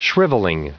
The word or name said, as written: shrivelling